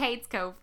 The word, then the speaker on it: Cades Cove: It's because people in East Tennessee can't pronounce the word Kate. – So it's Cade. – So that's it's it. Cade's Cove.